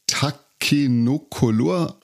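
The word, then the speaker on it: Takenoko: Tobias, wir haben uns gerade auf der Boardgame Arena getroffen und hatten Lust, spontan etwas zu spielen und haben uns für Takenocolor entschieden. Habe ich das richtig ausgesprochen? – Ich würde es so sagen, ja.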